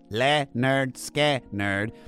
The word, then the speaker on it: Lynyrd Skynyrd: It's by Lynyrd Skynyrd from their 1973 debut album, pronounced Leonard Skinner.